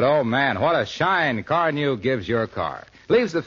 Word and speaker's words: Carnu: More car owners buy Johnson's Car New, you know, than any other car polish. It's spelled C-A-R-N-U.